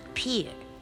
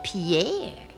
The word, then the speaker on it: Pierre: Not many people know it's pronounced Pier. They think it's Pierre.